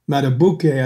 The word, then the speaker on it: Madubuike: And Justin Madubuike, I think is how it's pronounced, behind him is, I think, is an up-and-coming player.